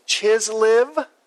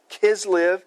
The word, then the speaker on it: Kislev: It says that this is the words of Nehemiah during the month of, some of us pronounce it Chislev, but some of us pronounce it Kislev, because oftentimes in Hebrew the CH makes a K. Kislev.